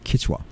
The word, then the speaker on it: Quechua: Quechua, did I say that right? – Quechua. – Quechua.